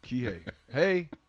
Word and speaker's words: Kihei: Florida State played them that way first, and that's the formula to beat in Virginia, because if Keywon Clark, why did you pronounce his first name Keywon? – Kihei Clark. – Okay, I'm sorry. Kihei. Hey.